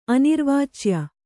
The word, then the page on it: ಅನಿರ್ವಾಚ್ಯ: anirvācya